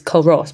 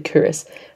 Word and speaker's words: Culross: So, went to Loch Lomond, and then we went to a little town called Cowrs, which is spelled Cowrss, so you'd think it'd be spelled, it pronounced Culross, but it's actually Cowrs, where I think various scenes in Outlander were filmed.